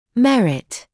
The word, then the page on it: merit: [‘merɪt]; [‘мэрит]